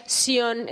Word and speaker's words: Sión: No, tú lo dices bien y tú pronuncias Sion, efectivamente, como yo pronuncio Sion. ¿Por qué? Porque está escrito con Z y es en, en, está en inglés, pero Sion, efectivamente, S-I-O acento N.